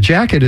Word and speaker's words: Jacquet: So we've got a tune called Straight Jacket, and the jacket is spelled J-A-C-Q-U-E-T after the famous saxophone player Illinois Jacket.